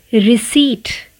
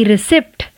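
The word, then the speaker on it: receipt: इसका उच्चारण है रिसीट ना कि रिसिप्ट 99 परसेंट लोग इसे रिसिप्ट कहते हैं इसमें भी पी साइलेंट है और इसे प्रोनाउंस करते हैं रिसीट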